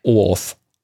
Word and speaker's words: OAuth: Ja, das ist natürlich so, aber wenn man ein E-Mail-Konto hat, wo man mit OAuth, wie spricht man das aus? – OAuth. – OAuth. O-A-U-T-H.